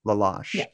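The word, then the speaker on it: La Loche: Am I pronouncing that correctly? Laloche. – Yeah.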